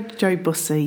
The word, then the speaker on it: Joe Bussey: And Joe Bussey. Or Bussey. Sorry. Have I got your name wrong already? – No, Joe Bussey.